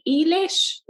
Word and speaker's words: Eilish: And I didn't really know who this Billy, I don't even know how to say her name. Billy Eilish? Is that Billy? – Billie Eilish. – Eilish, yeah.